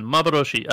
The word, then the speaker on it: Maboroshi: Maboroshi.